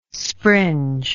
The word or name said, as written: springe